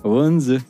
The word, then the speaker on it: Odense: Odense. – Ooh, interesting. Odense. Oh, we can listen. Yes, let's listen to this. Okay, this is how you pronounce that place in Denmark that I was. – Wunze.